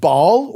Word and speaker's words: Baal: particularly one around a god called Baal, or sometimes pronounced Baal, B-A-A-L, and they've been worshipping him.